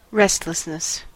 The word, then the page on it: restlessness: American English